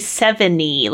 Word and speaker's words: Sevigny: It is, I assumed that her name was pronounced Chloe Sevigny, but it's actually Chloe Sevigny, like from what I found, like, they don't do a Nia. So it's a really, it's like, just totally silent G in this case.